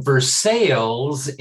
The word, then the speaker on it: Versailles: We are headed to Versailles, Indiana. And yes, that is the way it's pronounced.